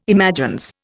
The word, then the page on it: imagines: Indian